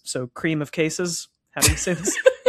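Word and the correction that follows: crème de cassis: Creme de cassis.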